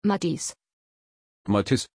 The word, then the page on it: Matthiys: German